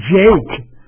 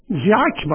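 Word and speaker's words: Jacques: آقای مارتین اینجا بعضی ها تلفظش میکنن جیک، در حالی که این ژاک باید باشه، برای اینکه اسم یه اسم اصلا فرانسویه، با اینکه خودش انگلیسیه هر شبه که، ولی فکر میکنم فامیلش ژاک باشه، در چیزای انگلیسی بهش میگن جیک.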